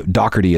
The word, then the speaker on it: Doherty: Oh, by the way, Doherty is how you do pronounce Pete Doherty's last name. That's the way the British do it.